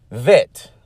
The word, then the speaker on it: bet: then it becomes a vet, which is pronounced with a V. But if you have the nikud in the middle of the letter bet, it is pronounced with a B, as with Abba, you see.